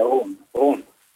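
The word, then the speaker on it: Rhône: Det begynner på R. – Ren? – Ja, det står Rone, er det Rende? R-H-O-N-E. – Ja, det er Rone.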